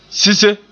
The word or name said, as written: sise